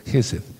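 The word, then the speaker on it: Gesed (חסד): Pero Geseth se escribe en la fonética hebrea J, la letra E de Ernesto, la letra S, la letra E de y la, de Ernesto y la letra D de dedo, Gesed.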